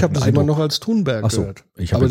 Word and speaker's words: Thunberg: Übrigens, so spricht man sie richtig aus. Thunberg. – Ne? Thunberg.